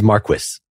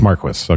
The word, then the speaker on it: Marquis: Just an FYI, I specifically pulled up a video just so I could see how to pronounce his name, and it is Marquis. – It's Marquis, okay.